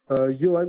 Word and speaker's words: Yohan: Johan Manuel, YOHAN.